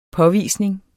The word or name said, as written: påvisning